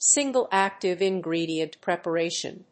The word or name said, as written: single active ingredient preparation